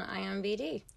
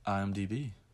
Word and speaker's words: IMDb: Oh, and look up Jason Harlow on IMBD. – IMDb.